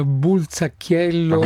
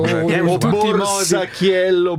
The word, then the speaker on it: Borzacchiello: Allora si scrive Borza come borsa con la Z, Borzacchiello. E non Bulzacchiello. – Borzacchiello, Borzacchiello.